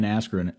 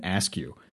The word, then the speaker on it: Askren: Even Michael Buffer announces Ben Askren as Ben Askew.